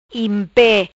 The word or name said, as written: Impe